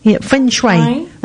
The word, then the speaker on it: Feng Shui: She has a guest coming into the studio talking about Feng Shui. Have I said that right? Feng Shui. That's it, yeah.